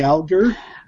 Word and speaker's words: Alger: I guess Brian found out from some people that the name was Alger, Fanny Alger. – I think she said Alger. – Alger. Okay. – Alger. That's what she told me. – All right. So I've really been mispronouncing it all these years.